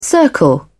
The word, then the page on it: circle: British English